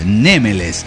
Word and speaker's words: Némeles: Con respecto a cómo se pronuncia mi nick, la verdad me gusta más Némeles, porque acentuado en la primera sílaba suena como más egipcio, digamos.